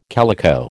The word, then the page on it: calico: American English